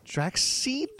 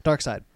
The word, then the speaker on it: Darkseid: How do you pronounce this guy's name? It's a little-known character created by Jack Kirby in like the 1700s or whatever. – 70s? – That's his name. Tell me his name. Say my name. – Draxseed? – Nope. – Who's Draxseed? – Nope. – Dracula. Oh, that's a typo. Darkseid.